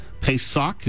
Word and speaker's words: Pesach: And this Passover word, Pesach, is how you pronounce it in Hebrew. Pesach literally means to protect or to shield with wings like a mother bird her young.